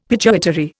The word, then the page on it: pituitary: Indian